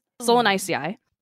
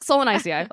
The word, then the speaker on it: Solanaceae: And popular vegetables in the nightshade family, which, by the way, the nightshade family scientific name is Solanaceae. Okay. I even looked up how to pronounce this. I never forgot. But Solanaceae, I'll just go with that, include tomatoes, eggplants, bell peppers, and other various peppers, and potatoes.